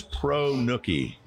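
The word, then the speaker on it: pronuke: The word is pro-nookie. That's P-R-O-N-U-K-E. Pro-nookie.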